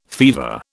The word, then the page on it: fever: British English